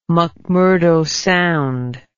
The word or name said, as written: mcmurdo sound